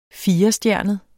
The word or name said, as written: firestjernet